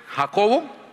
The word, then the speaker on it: Jacob: Tal vez el mayor de los apóstoles, Jacobo, el menor, Juan, su hermano. Algo sobre Jacobo. Jacobo, su pronunciación, Jacob. Jacobo, Jacob, como si fuera con I, Jacob.